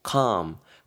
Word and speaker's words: calm: C-A-L-M이 되는데요. L은 여러분 발음하지 마시고 무음 처리하시고 calm, calm 이렇게 하시면 되겠습니다. calm someone 하게 되면은 말 그대로 뭐 누가 진정시키다라는 느낌 전하실 수 있는 동사로 활용할 수가 있습니다.